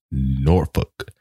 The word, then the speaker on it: Norfolk: So I think they pronounce it out there like Norfolk. Like one of those letters does not get pronounced.